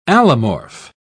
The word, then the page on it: allomorph: British English